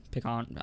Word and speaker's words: pecan: Pecan. Oh, no, man. How do you pronounce it? Pecan or pecan? – Pecan.